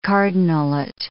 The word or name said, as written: cardinalate